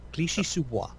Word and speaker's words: Clichy-sous-Bois: Clichy-sous-Bois.